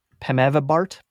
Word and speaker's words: Pemivibart: Also, the FDA did an emergency approval of pre-exposure prophylaxis for COVID called Pemivibart, which is a dumb name because it rhymes with fart, and nothing should rhyme with fart if it's a drug.